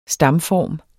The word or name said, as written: stamform